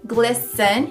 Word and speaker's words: glisten: Tem esse T, o T é mudo, glisten, que nem na palavra listen.